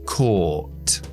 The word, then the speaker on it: caught: En pasado, caught. Caught. Pronunciación estadounidense, caught. Caught.